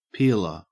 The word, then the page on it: peeler: Australian English